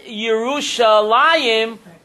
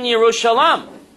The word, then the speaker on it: Yerushalayim: So why do we pronounce the word Yerushalayim when it's written Yerushalam?